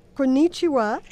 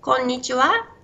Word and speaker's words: こんにちは: Konnichiwa. – Konnichiwa, which is hello.